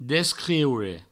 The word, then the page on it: descriure: Provençal; descrieure